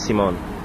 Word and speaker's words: Simon: My parents were German, so they use the German pronunciation of Simon, which is Simone.